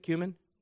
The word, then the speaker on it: cumin: Is that how you say it, cumin? Yeah? Good.